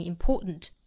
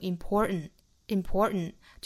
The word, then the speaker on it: important: 英音这个词我们是读成 important 但是美音是要读成 important 就好像是一个 n 的发音但其实是由鼻腔爆破出来发音的 important